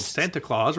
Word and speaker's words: Santa Claus: They're making clear, you spell it S-A-N-T-A-C-L-A-U-S. – Right, but it's pronounced Throat Warbler Mangrove. No, it's pronounced Santa Claus, apparently.